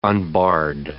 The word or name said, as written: unbarred